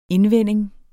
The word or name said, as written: INDVENDINGERNES